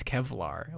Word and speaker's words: Kevlar: The nerd said that it's Kevlar. Like, the exterior of this thing is Kevlar. Although, I remember him pronouncing it Kelvar. The fucking nerd pronounced it Kelvar.